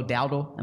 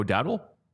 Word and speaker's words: Dowdle: It's what Rico Dowdle. Am I pronouncing it correctly? – I believe you are. Okay. I thought he was a wide receiver though. Uh, Rico Dowdle.